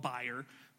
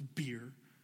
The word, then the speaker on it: bier: I had to look it up because I wanted to call it buyer, but it's beer.